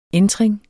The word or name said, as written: entring